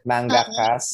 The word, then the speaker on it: mangakas: Eh, basta it's about the publishing industries, the mangakas. Mangakas, tama ba, Sari? – Manga, diba? – Mangakas. Mangakas. Yun ba yung writers? Tama ba yung pronunciation ko? – Oo, tama, tama.